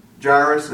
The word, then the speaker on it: Jairus: And behold, there came a man named Jairus, and he was ruler of the synagogue, and he fell down at Jesus' feet and besought him that he would come into his house." Jairus, or Jairus as some people pronounce it, was a man of no small accomplishment.